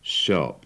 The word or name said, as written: shop